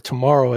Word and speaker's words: TMRW: So I did a little research, and TMRW, or Tomorrow, as I'm told, is how we're supposed to pronounce it, seems to be a subsidiary of a company that was formed, a corporation in Delaware, I think a few months earlier.